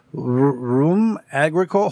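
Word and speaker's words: rhum agricole: And this liquor is called rum agricole. Hold on, I got a pronunciation. It looks like rum with an H, agricole, and it is actually pronounced rum agricole.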